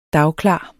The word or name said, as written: dagklar